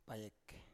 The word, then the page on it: payek: Biak